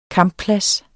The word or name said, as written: kampplads